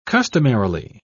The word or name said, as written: customarily